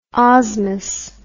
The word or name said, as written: osmous